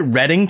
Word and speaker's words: Reading: Isn't it isn't it Reading, Pennsylvania? it probably is.